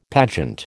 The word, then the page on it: pageant: American English